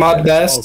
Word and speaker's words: Ahmed Best: It just didn't ever happen to come to um because they basically I guess him and Ashad or whatever, how you say his name uh, ah, uh, the dude to play Jar Jar Binks yeah um, Ahmed Ahmed Ahmed Best oh,